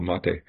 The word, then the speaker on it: Mate: I know it looks like it's spelled mate, but it's actually comes from South America, and it's named after a drink, a highly caffeinated kind of tea there called mate.